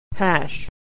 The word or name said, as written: hash